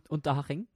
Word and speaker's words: Unterhaching: Og så kan du hjelpe meg med uttalelsen av det neste. Han gikk gratis til en U-harting. Unterhaching. Unterhaching. Unterhaching.